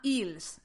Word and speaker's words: Eels: una banda que se llama Eels, se escribe E-E-L-S.